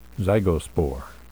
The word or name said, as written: zygospore